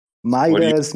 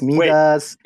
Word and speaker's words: Midas: You can call me Midas, Midas, Daddy, whatever you want. Yeah. Well, I think it's like Midai or something like that.